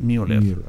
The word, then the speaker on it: Müller: Él dijo, no es Miller ni Müller, es Müller. Por eso eh, vamos a tener que aprender a...